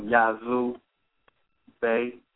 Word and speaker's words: Yazoo Bey: You can go on Yazoo Bey, and you can just look me up, and my name is spelled Y-A-Z-O-O, you know, like Yazoo, Mississippi, and so forth. And Bey, B-E-Y, you know.